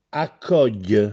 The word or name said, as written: Accògghj